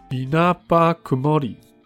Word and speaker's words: 稲葉曇: 然后其实我一直很喜欢一个创作者，他是那个稻叶檀，哎，我看一下怎么念哦 i n a b a Kumori 这个 Vocalo 的歌曲创作者，就 Vocalo P 啊，他的歌曲风格其实还蛮好辨认。